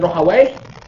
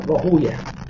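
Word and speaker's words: Rahuyah: Suatu ketika Ishaq bin Rohaway, ya, atau Ishaq bin Rohaway, bisa dibaca dua, ya, Rohaway atau Rohuyah. Kebanyakan ahli hadis membacanya dengan Rohuyah, ya, karena kalau dibaca Way, Rohaway, Way ini menurut sebagian mereka adalah nama nama syaitan atau nama iblis, sehingga mereka menjauhi penyebutan wai, sehingga bacanya rohuya, ya Ishaq bin rohuya.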